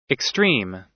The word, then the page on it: extreme: American English